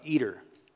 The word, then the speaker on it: Eder: This tower was called the Tower of Eder. E-D-E-R. My wife said I have to spell it. E-D-E, because it sounds like I'm saying something that Hall & Oates wrote. Man-eater. That's not it. E-D-E-R. Migdal-eater. M-I-G-D-A-L.